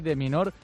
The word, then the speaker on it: de Minaur: Se escribe de Minaur, pero se dice de Minaur.